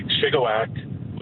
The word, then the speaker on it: Shigawake: Like Shigawake, which is how the locals pronounce it around there, has had an agricultural festival like you would imagine agricultural festivals, with a horse pull and baking competitions for around 110 years.